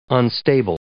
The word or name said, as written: unstable